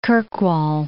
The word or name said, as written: Kirkwall